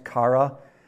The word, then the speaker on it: chara: The Greek word for joy is it's spelled C-H-A-R-A. It's pronounced kara.